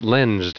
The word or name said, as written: lensed